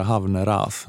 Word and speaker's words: Havnerås: Sara Havnerås. Norsk. Sara Havneras. Ja, det är dubbel A. Det ska uttalas Ova.